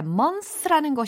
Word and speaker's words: months: 자, months라는 것이 달에 해당하는 단어잖아요. 근데 몇 달이라고 복수형이니까 m-o-n-t-h-s란 말이에요. 요거 발음이 어떻게 되냐면 months가 아니라 months 요렇게 어, months 이렇게 자연스럽게 처리하시면 됩니다.